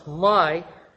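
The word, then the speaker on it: my (מי): And in Hebrew, the word "who" is "me," which we pronounce "my," and the word "like" is "ka."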